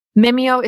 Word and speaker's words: Mimio: So Mimio is spelled M-I-M-I-O, Mimio.